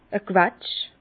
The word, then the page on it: e Quetsch: Bas-Rhin; Herrlisheim